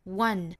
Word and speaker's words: one: 我们看这个词虽然 one 是元音字母打头，但是它第一个发音应该是一个半元音，就是辅音的 w 的发音，所以是 one one。